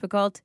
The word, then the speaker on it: Picoult: This is a book written by Jodi Picoult, and if you're like me and always forget how Picoult is actually spelt, it's P-I-C-O-U-L-T. Okay, and it's not pronounced Picoult, it's pronounced Picoult.